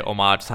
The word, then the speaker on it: homage: Half the time I say homage, half the time I say homage. I think it's homage. Homage. You don't pronounce it. It's like O, like O-H. Maj.